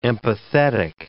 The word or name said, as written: empathetic